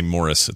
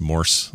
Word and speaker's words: Morse: People always think we say Morris. It's not. It's Morse. Like Morse, I believe is how you spell it. – Morse code. – Like the code.